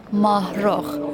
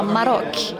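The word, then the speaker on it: Mahrokh: Och första gången jag hörde det namnet uttalas så var det någon som sa Marok. – Just det, för det är ett KH i slutet.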